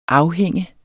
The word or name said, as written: afhænge